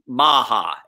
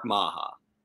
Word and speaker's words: Maher: Maha, M-A-H-E-R, Mark Maha.